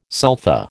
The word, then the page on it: sulfa: American English